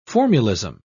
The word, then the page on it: formulism: British English